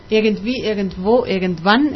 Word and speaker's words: Irgendwie, irgendwo, irgendwann: Irgendwie, irgendwo, irgendwann es una canción en alemán de Nena.